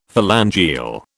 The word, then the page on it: phalangeal: British English